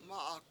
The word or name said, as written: mA7ak